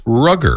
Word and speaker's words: Rogger: Okay, and if you just saw his name written out, you'd probably think his name was Roger. – Mm-hmm. – R-O-G-G-E-R. But it's pronounced Rugger.